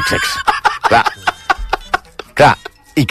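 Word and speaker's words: sechs: El 6 en alemany es pronuncia sex.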